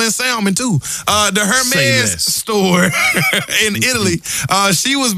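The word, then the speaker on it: Hermès: Hermes? – Is that how you... See, I always hit the H. – The H is silent.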